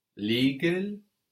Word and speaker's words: légal: Legal. Legal. Legal. We have an E here. No, an E has an accent on it, so it's a stronger E.